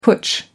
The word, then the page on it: putsch: American English